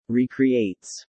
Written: recreates

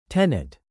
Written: tenet

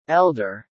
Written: elder

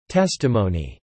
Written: testimony

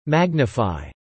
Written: magnify

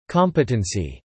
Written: competency

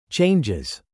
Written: changes

